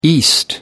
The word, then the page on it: east: American English